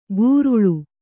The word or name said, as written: ಕುರುಳು